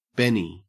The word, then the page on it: benny: Australian English